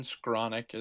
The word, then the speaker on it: Skowronek: Yeah, I think Ben Skowronek is a good complementary piece.